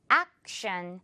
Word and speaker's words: action: 우리 이거 정말 흔하게 말하는 action. 영어로 발음을 할 때는 이 act 부분 조금 더 입을 크게 길게 늘어뜨려서 act 이렇게 발음해주면 돼요. 평범하게 action이 아니라 action.